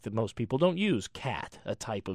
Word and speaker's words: qat: There are acceptable words like QAT, pronounced cat, and TRANQ, or trank.